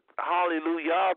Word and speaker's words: hallelujah: When you say hallelujah, it's spelled the end of hallelujah, the spelling is J-A-H, but nobody say hallelujah because the church world know there wasn't the J, so many people just put the Y there and say hallelujah.